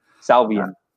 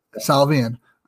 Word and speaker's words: Salvian: Is it Salvian? – Salvian. – Salvian.